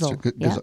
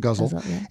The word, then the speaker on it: ghazal: ghazal, I believe you pronounced ghazal. – It. Ghazal.